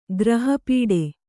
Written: ಗ್ರಹಪೀಡೆ

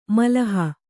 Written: ಮೆಲುಹು

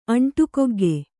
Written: ಅಂಟುಕೊಗ್ಗೆ

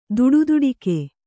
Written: ದುಡುದುಡಿಕೆ

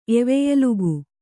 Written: ಎವೆಯಲುಗು

